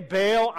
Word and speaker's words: Baal: As a side note, most people say Baal. I say Baal because that's how you pronounce it in the Hebrew, Baal. But that's really awkward.